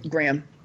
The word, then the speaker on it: Graham: I'm not sure how he pronounces his name. – Correct. Graham. Although he says it with a southern accent.